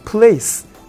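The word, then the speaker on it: plaice: えーとプレイス発音は場所と同じだけど、えーと、スペルが違う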